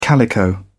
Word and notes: calico: British English

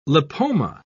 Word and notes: lipoma: British English

